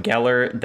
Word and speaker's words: Geller: Yeah, so on YouTube, I'm just Jacob Geller. That's spelled G-E-L-L-E-R.